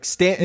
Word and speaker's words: Stanton: See, it's like you look at it and it might rhyme, but it doesn't really when you say it. – You got to say it like Manhattan. Like Stanton, you gotta like Manhattan.